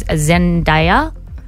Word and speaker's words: Zendaya: He's hosting it alongside Jennifer Lopez, Zendaya.